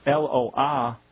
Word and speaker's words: Eloah: Well, it's pronounced Eloah, and they're spelling it Elowahh.